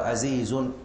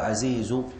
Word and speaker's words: عزيز: يقول رحمه الله: عزيز مروي اثنين أو ثلاثة، مشهور مروي فوق ما ثلاثة. قوله عزيز بالضم من غير تنوين لضرورة النظم، لا يقال عزيز بل يقال عزيز.